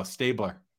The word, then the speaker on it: Stabler: Stabler.